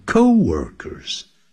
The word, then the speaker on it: co-workers: 关系代词 whom 作为 works with 的宾语，她把它省略掉了。其实也就得 She loves her job and her co-workers. 也就是她的同事。同事叫 co-workers, C-O, hyphen 就是短线符号、连字符号，再加上 W-O-R-K-E-R-S, 那念的时候啊，不要念成 co-workers, 叫 co-workers, co, 啊，放在重音在第一个音节。Robert is worried, Robert 却不这么担心 because he knows that they will make great parents,因为他知道他们将来会成为 make 这个字不当制造，他可以当制造。